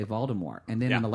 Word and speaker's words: Voldemort: Like, I'm sure that they're going to talk about Voldemort, which is actually no T is pronounced.